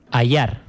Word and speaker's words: Adar: Y Adar con dos Ds, que la pronunciación sería... – Ayar. – Ayar, claro. Uh-huh. Ayar, como Yancha... – Eso es, ¿sí? Eso es. O como Maggi. – O como Maggi, efectivamente. Bueno, pues Ayar, Arratsalde on Gabón.